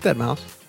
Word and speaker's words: Deadmau5: How about we just fucking get straight to the point, and I'm going to butcher their name, but it is Deadmau5, right? – Yeah. Yeah. It's Deadmau5.